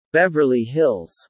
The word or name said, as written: Beverly Hills